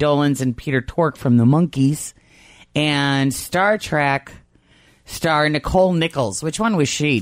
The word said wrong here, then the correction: Nichelle: Isn't it pronounced Nichelle? – Is it? Nichol- yeah, I think so.